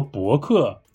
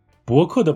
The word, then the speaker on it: 博客: 但是我发现有很多听众朋友们都犯了一个相同的错误，大家好像有点分不清什么是播客，什么是博客。哈哈，今天我就来告诉大家，播客和博客有什么不一样。首先，这两个词的拼音是完全一样的，只是第一个字的声调不一样。播客的“ 播” 是一声。博客的“ 博” 是二声，播客，广播的“ 播”，客人的“ 客”。